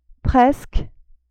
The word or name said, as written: presque